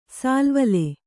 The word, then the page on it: ಸಾಲ್ವಲೆ: sālvale